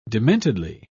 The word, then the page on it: dementedly: British English